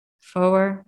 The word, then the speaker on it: Foer: Am I pronouncing that right? – Foer, I think.